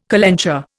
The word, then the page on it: calenture: Indian